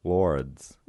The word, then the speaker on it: Laurids: Lords. – How do you spell that? – L A U R I D S. Lords.